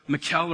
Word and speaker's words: Michal: In fact, it's the other daughter, Michal or Michael, however you want to pronounce it in Hebrew, is basically in love with King David.